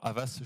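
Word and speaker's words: Havas: Alors, avant de fonder, cofonder Cogni, euh, un autre petit passage dans ton cursus, si je ne m'abuse, chez alors, Avas, je le prononce bien Exactement.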